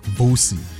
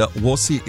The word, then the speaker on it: Worsie: for a second I thought he said Vosi. And then Vosi is V-U-S-I, and Watsy is W. O-R-S-I-E.